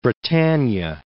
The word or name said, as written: Britannia